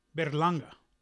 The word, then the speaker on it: Berlanga: And Bellaranga? Did I say his name again? Berlanga.